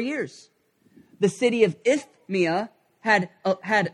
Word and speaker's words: Isthmia: there's a city called Isthmia. Okay, I don't have a lisp. It's spelled weird. I-S-T-H-M-I-A. Isthmia.